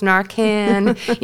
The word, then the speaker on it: Narcan: they were pronouncing Narcan, Narcon, and I'm like, no, it's Narcan, you know, things that like an audience member like myself would really, it would jump right out.